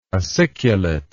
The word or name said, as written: aciculate